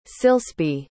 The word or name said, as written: Silsby